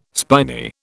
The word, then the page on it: spiny: British English